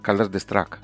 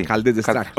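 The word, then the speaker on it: Caldes d'Estrac: En estos días publicaste también otro que era el de, cómo se pronuncia, Caldes de Strack. Sí, Caldes de Strack. ¿Se llama así? Sí, sí. Caldes de Strack.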